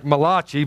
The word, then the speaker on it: Malachi: And then we get to Malachi, and he's like, God says, listen, if you read the book of Malachi, it's spelled like Malachi, but it's pronounced Malachi.